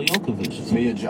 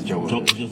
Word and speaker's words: Jovovich: I think it's Jovovich. – Jovovich? Yeah, okay. I didn't know you pronounced the J. – You know what? I think if you pronounced it like the way she does, wherever that name is from, I think you don't, but I've heard Jovovich.